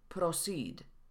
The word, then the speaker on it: proceed: Schwa może się chować za różnymi samogłoskami w pisowni. W filmiku o stronach internetowych pojawiło się słowo proceed. Wymówione było proceed.